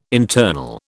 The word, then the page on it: internal: British English